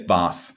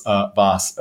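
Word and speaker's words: Waas: It's W-A-A-S, but it's pronounced Vaas. Is that right? – So, yeah. So in Germany, you would say Vaas. – Yeah. – And so I'm wondering if, you know, the skier Vaas, V-O-S-S, if that used to be my name and they just changed it so that Americans would pronounce it the right way. But yes, Vaas.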